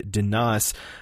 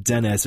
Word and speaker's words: Dannas: Her character will be named Danas. That's how I think it's pronounced. It's not Dennis. It's spelled D-A-N-N-A-S.